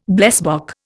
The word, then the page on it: blesbok: Indian